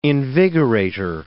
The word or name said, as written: invigorator